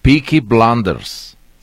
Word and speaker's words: Peaky Blinders: Ya hasta busqué en Google Translate para pronunciarlo bien. Peaky Blinders se llama la serie.